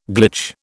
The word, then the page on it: glitch: British English